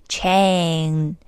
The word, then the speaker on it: chain: 中国连锁企业 chain，注意这个元音加 n 的这个发音是我们中国同学发音的重灾区，记住一定要发 n 发出来 chain n。